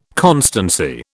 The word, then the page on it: constancy: British English